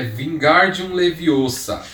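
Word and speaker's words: Wingardium Leviosa: É Vingardium Leviosa. – O Gar é pronunciado longo.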